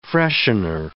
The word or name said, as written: freshener